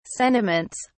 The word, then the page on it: sentiments: British English